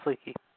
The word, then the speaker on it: Leakey: When he went by the name Roman Leakey, I guess that's how you pronounce it, I guess. – Yeah, it's Leakey.